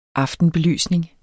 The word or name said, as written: aftenbelysning